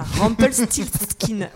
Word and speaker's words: Rumpelstiltskin: C'est vrai, Rumpel Siskin. – Ah, non, il y a un T qui manque, c'est Rumpel Stilt. – Ah, voilà, Rumpelstiltskin. Et voilà.